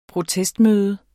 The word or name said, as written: protest